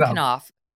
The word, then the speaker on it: Knauf: On October 26th, 2018, just as Harry and Meghan were flying from Tonga to Sydney for the Invictus Games, Jason, is it Knopf? Is that how you pronounce his name? – Knopf, yeah. – Jason Knopf,